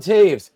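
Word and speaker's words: Toews: And after last night, Devon Taves, there you go, Anthony, actually said his name correctly, called out his teammates.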